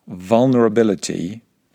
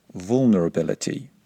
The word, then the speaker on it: vulnerability: Okay, if you want to speak with standard, non-regional British English pronunciation, then you would pronounce the word vulnerability with a short U sound at the beginning. Be careful not to pronounce it vulnerability.